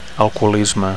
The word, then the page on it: alcoholisme: Catalan